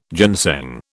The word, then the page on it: ginseng: British English